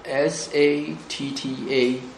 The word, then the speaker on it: satta: 如果是巴利文是念成 sattva， s a t t a SATTA 啊，这个是巴利文。